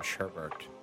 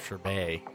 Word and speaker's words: sherbet: Yeah, there's no second R in sherbet. – So it's sherbet. – It's sherbet. Sherbet. Sherbet.